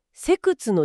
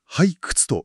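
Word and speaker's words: 背屈: でもう一つ、ミリ単位の技術としてセクツの重要性が解説されていますよね。 – はい、セクツ、ハイクツと読みます。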